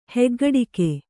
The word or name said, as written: ಹೆಗ್ಗಡಿಕೆ